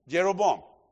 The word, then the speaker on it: Jeroboam: Well, Jehoroban, now you mentioned that name, the king that took over the northern territory. Pastor, how do you pronounce his name? Jeroboam.